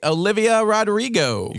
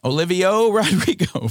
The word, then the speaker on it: Olivia Rodrigo: It was a little Olivia Rodriguez. Is that not right? Olivia Rodrigo. – You got to drop that last syllable. Like, pretend it's a Grammy. – Olivia Rodrigo.